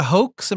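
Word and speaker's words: Hoeks: Sylvia Hoax, am I pronouncing that right? – Yep.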